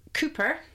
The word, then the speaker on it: Cupar: And then, of course, you've got Cooper, which is actually spelt Cowper, kind of C-U-P-A-R, Cowper, but it's actually Cooper.